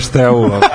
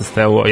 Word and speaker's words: Steaua: I onda, sam ja ispravio ljude da je Steaua. – to je najgori mene. – Tako da sam tri put googlao danas kako se izgovara. Znači, našao sam tri YouTube videa da vidim kako se izgovara Steaua. Jeste Steaua, jeste zvezda.